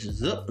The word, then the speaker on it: Zook: It's Zook, like look, Zook.